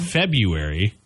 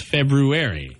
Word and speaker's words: February: There's an R in there. It's not a silent R. There's two R's, actually, but it's the one after the B. February. – How about the fruity ice cream? It's not ice cream, but it's S-H-E-R-B-E-T, sherbet.